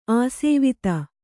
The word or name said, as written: ಆಸ್ವಾದ